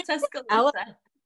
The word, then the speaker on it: Tuscaloosa: How do you say it? Tuscaloosa? – Yeah, Tuscaloosa.